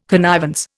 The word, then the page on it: connivance: Indian